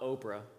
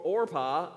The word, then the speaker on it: Orpah: One's name was Orpah, not Oprah. I used to say that wrong. It's like, man, he got married to Oprah. Orpah, and the other was Ruth.